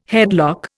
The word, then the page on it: headlock: Indian